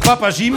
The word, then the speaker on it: Papa Jim: Papa Jim, je crois que c'est ça. Hein. Donc, Papa Jim, on le prononce comme ça.